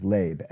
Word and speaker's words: leb: Heart there in Proverbs 3:5 is leb, L-E-B, it's a long E, it's mind.